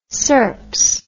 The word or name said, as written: serps